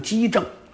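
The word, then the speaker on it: 奇正: 但是呢有些兵家认为在这儿读就得读成机正，这个棋念机。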